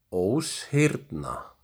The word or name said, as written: Óshyrna